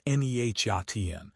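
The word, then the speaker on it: Nejatian: Kaznejatin, pronounced Nehyatin, took the helm at the real estate firm Open Door and immediately scrapped the standard playbook for organizational change.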